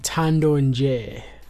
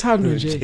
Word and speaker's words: ThandoNje: Today we are doing a review for a project called Stillness by ThandoNje. The Nje is silent.